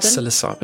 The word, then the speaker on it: psilocybin: Psilocybin? – Psilocybin.